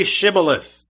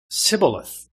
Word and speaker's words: Shibboleth: And if he said, "No," then they would say to him, "Then say Shibboleth." And he would say Sibboleth, for he could not pronounce it right.